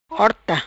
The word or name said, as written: Horta